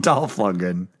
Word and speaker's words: Dolph Lundgren: Lolf Dungren. – Lolf Dungren. – Am I saying it right? I'm saying it wrong. – Dolph Lundgren.